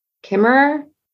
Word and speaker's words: Kimmerer: I think her last name is pronounced Kimmerer, and it she has indigenous family.